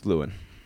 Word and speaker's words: Llewyn: Oh, he was in Inside Llewyn Davis, Oscar Davis. – Oh, okay. – Oscar, Inside Llewyn Davis, yeah. – I think it's Llewyn. – Is it Llewyn? Not Llewellyn? – You put an extra syllable in there. – Okay. – L-L-E-W-Y-N.